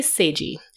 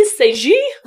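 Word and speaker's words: Sagée: The most famous case of doppelganger is the case of Emily Sagi, and I'm sure I'm pronouncing that wrong because it's French. – Sounds like it's wrong. – S-A-G-E-E. For French. – Emily S. – Okay, how would you say it with a French accent? – Emily Sagi? – That was excellent.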